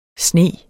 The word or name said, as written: SNE